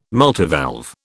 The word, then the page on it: multivalve: British English